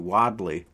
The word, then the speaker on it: Wadley: Mark got the pronunciation right on that one too, Wadley.